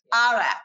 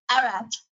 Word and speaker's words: Arak: I think in Israel, it's called Arak. Arak, sorry. It's my bad pronunciation. But Arak is, think about ouzo.